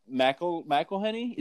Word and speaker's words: McElhenney: Rob McElhenney, is that how you pronounce his name? – Oh, yeah.